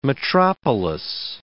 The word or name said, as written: metropolis